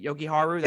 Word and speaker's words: Jokiharju: So they ended up re-signing Casey Mittelstadt and Henry Jokiharju, right? Jokiharju, that's how it's pronounced? – Yes.